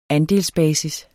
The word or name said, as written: andelsbasis